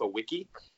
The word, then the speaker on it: Wicki: We got to hear from the new fire head coach, Rafa Wicki. W is the pronunciation. He does not pronounce it like the German W with the V.